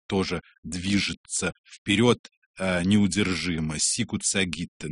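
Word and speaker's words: sagitta: Сагитта, конечно, он поет, как полагается, в итальянском произношении латыни, и здесь он абсолютно прав.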